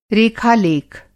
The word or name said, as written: रेखा-लेख